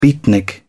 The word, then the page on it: beatnik: British English